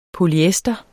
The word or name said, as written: polyester